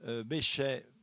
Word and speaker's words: Bechet: Alors, le prénom Paul, P-A-U-L, Bechet, B-E-C-H-E-T.